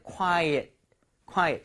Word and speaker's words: quiet: 자, 발음 한번 해볼게요. Quiet은 그냥 정말 quiet. 하지만 조용한이라고 할 때는 quiet, quiet. So quite, quiet, quite, quiet.